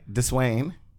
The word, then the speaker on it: Deswayne: The Dez, when she says my name, she says Dez. But whenever she pronounced my full name, she'd be like, Deswayne.